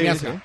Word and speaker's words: Miazga: Quita a Miazga, a Miazga, se dice así, ¿no? – Sí, sí, Miazga.